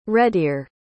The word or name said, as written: redear